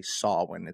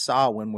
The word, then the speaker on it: Samhain: A lot of people will say that, but the word is actually Samhain.